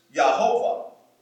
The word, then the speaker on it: Jehovah: And of course, a German would pronounce this Yehovah. Yehovah. And then in English, we get the word Jehovah from that.